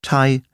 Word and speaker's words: Thai: Außerdem muss man die Ausnahmen kennen. Als denglische Patienten brauchen wir uns nämlich gar nicht mit der Aussprache von TH abzumühen, wenn es ganz einfach wie ein T gesprochen wird, in Namen wie Theresa, Thomas, Thompson, Thames, Thai, Lesotho oder Thyme, dem Gewürz Thymian.